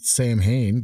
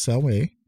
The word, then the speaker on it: Samhain: So, when the Romans conquered the British Isles, the tradition of Samhain, it's spelled Samhain, but it's pronounced Samhain, or Samhain, I'm sorry.